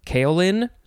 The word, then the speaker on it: kaolin: I think it would be kaolin. Maybe it is spelled K-A-O-L-I-N.